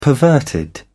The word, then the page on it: perverted: British English